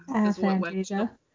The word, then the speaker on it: aphantasia: Aphantasia? – Aphantasia. – Aphantasia. – Merriam-Webster's pronunciation of it is aphantasia.